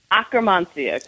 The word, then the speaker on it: Akkermansia: Akkermansia? – Akkermansia.